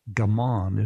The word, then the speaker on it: gaman: First, let me just say, at least with my pal, there's a word, a Japanese word for endure, and it's gaman. Is that the pronunciation, Norm? – Right.